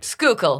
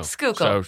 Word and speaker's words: Schuylkill: Schuylkill. – There we go. – Schuylkill.